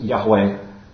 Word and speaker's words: Yahweh: So God's covenant name has four consonants in the original Hebrew, YHWH, and it's pronounced Yahweh.